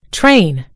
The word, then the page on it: train: British English